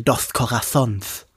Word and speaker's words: Dos Corazones: da viel mehr so lispeln. Dos Corazons. Dann ist es richtig ausgesprochen.